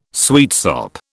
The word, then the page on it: sweetsop: British English